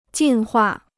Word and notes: 进化: jìn huà

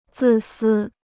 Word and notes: 自私: zì sī

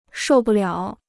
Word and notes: 受不了: shòu bù liǎo

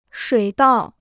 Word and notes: 水稻: shuǐ dào

